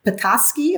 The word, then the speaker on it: Petoskey: Petoskey, am I pronouncing it correctly? – Oh, you're so right.